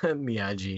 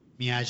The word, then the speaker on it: Miyagi: Yeah, Miyagi. – So if you guys didn't know how it was said, would you have pronounced it that way, too? – Yeah, with a G-I at the end.